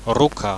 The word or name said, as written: ruka